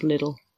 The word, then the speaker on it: Lidl: It's pronounced little.